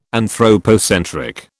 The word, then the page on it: anthropocentric: British English